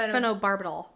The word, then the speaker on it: phenobarbital: But yeah, they were the, say the phenobarbital. – I think it's pronounced phenobarbital. – Barbital.